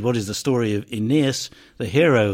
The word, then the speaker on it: Aeneas: His name in Latin, and indeed in Greek, consists of three long syllables. Aeneas. So that's his name, Aeneas.